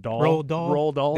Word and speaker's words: Roald Dahl: It's a Ronald. I don't know how to pronounce his name. – Roald Dahl.